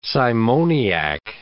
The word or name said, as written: simoniac